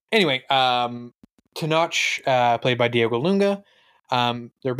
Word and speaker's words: Tenoch: I've been saying Tenoch with the ch sound. I think that's how they say it in the movie too.